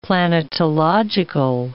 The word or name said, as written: planetological